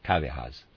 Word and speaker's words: Kávéház: Readers aside, that word Kávéház I'm pronouncing it that way. I'll spell it. It's K-A-V-E, and then H-A with a mark over it, Z. And I'm pronouncing that Kavehaz, but I'm making that up.